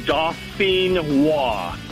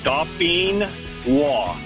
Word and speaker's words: Dauphinois: All right, so pronounce that for me one more time so I don't sound like a complete idiot when I order them. – No problem. It's, like I said, it's French. It's Dauphinois.